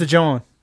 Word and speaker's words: jawn: And it's spelled like Lawn. J-A-W-N. – But it's a John. – It is a John.